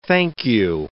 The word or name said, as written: thank you